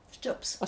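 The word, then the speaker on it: steups: Stjups. – A Stjups. – A Stjups. – Yeah. – Right, can you do it? – Yeah, you just suck your teeth together like this.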